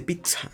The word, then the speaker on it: pizza: Y lo googleamos y llegó a la idea, eh, llegábamos al acuerdo, gracias a la definición de Google, que la doble pronunciación de la Z suena un entonces dijimos, ah, verga. Se dice pizza, pizza, puro platillo. ¿Sí? Entonces es pizza.